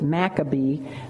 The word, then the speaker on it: Maccabee: So great, in fact, that he became known as the Maccavet, which is the Hebrew word, um, we pronounce it Maccabee, the Hebrew word for hammer. So he became known as Judah the Hammer because he just was hammering away at the enemy.